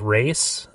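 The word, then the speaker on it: Ra's: You had Race, which is weird that they pronounce it differently, because I always pronounced it Ross.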